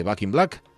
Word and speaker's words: Back in Black: de, de Back in Black.